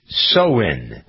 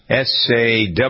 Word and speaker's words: Samhain: Well, it was a Celtic, good dog, Celtic festival pronounced Samhain, Sawhain, period between the autumn equinox and the winter solstice, because we're halfway through that period.